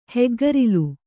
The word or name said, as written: ಹೆಗ್ಗರಿಲು